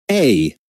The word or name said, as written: Aa